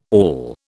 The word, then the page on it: awl: British English